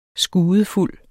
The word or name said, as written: skudefuld